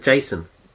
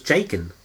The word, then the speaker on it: Jacen: Do you pronounce it Jason? – Yeah. – Oh, I always thought it was Jakin. – Oh, there you mm, go. – No, that'd be a K.